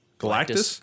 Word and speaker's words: Galactus: I mean, we keep hearing Galacticus. Wait, I said that right? – What? Galactus? – Galactus.